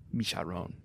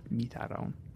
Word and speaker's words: Mitterrand: Mitterrand.